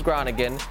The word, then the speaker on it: Groningen: It was Groningen. Completely has a kills it, has a great. What is it? What I say? – Yeah, you nailed it. You nailed the pronunciation.